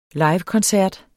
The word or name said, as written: livekoncert